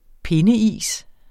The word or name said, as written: pindeis